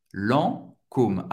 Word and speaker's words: Lancôme: A-I-N tem o son do 1. Guerlain. Guerlin. Lancôme, Lancôme, Lancôme. A-N, tem o som do an et você vai prononcer o O bem, bem forte. Lancôme, Lancôme, acaba no M.